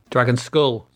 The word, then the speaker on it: Dragonskulle: Dragon Skull, spelt with an E.